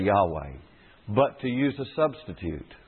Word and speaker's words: Yahweh: Those four letters are pronounced Yahweh, in all likelihood, Yahweh.